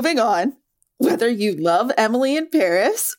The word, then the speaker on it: Emily in Paris: Emily in Paris is supposed to be pronounced with a French accent, so Emily and Paris rhyme.